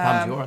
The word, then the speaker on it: Palme d'Or: It won the, forgive pronunciation, is it the Palme d'Or at... – Yeah, Palme d'Or, I think.